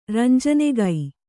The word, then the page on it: ರಂಜನೆಗೈ: ranjanegai